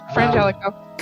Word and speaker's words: Frangelico: Thank you for introducing me to Frangelico. And am I saying it right? Gee, Frangelico. – Yeah, Frangelico.